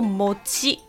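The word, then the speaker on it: 餅: 不过呢，在日文你看到“饼” 这个汉字，它读作 “mochi”。那大家听到 “mochi”，你大概知道我在说什么了吼。“mochi” 翻成中文应该是麻薯的意思，就是麻糬。那日文的字典里面有讲了吼，所谓的 “mochi” 呢，是糯米蒸熟之后去捣它，捣烂之后再塑形的东西。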